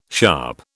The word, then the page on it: sharp: British English